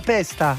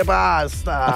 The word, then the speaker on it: pasta: Quasi correggiamo la pronuncia di pasta. Se all'estero dicono male la pesta, la posta, la pista, no, sorella, si dice pasta.